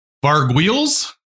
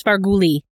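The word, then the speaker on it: Vargouille: I would say as a kid in the 80s, I would have pronounced this Varguli and been wrong.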